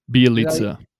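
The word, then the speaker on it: Bjelica: Bielitsa, I think that's how you said you pronounce his name, right? – Bielitsa. – So I did get it right this time.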